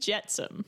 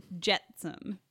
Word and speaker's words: Jetsam: Flotsam and Jetsam. – Jetsam. Okay. – Not like the Jetsons. It's different.